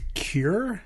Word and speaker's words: Cuir: This is, I want to say, Cure. I believe that's how you say it. C U I R.